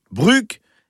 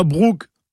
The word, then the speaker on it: brug: B-R-U-G, on ne peut pas faire plus simple. Alors, côté prononciation, je rappelle comme à chaque fois que le U en breton, c'est U. Donc, Brug et pas Brug. Ce serait pas joli.